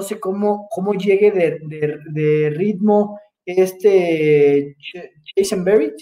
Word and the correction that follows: Cushenberry: Cushenberry. Cushenberry, perdón, siempre lo digo mal.